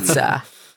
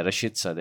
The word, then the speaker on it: Rashica: Oh my God, I'm butchering his name every time. Rashitska. – You're giving Yasin shit it's for karma, bro. – I don't know what happened. He just basically. Rashitza. Yeah, Rashitza. There we go.